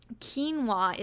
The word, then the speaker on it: quinoa: Quinoa is the other grain, and that's spelled Q-U-I-N-O-A, spelled Q-U-I-N-O-A, and pronounced quinoa.